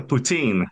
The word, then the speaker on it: poutine: I'd be governor general for a poutine. Is that how you pronounce that? – Well, that would be a poutine, actually.